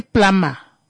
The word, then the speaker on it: plumber: Kono ki plama. The B is silent. Kono baba mwi, bakai eza, they will pronounce it as a plumber. Kakuri, habazibikuri, B is silent. Kona kuri, hamvulela msho. B is silent, maskua.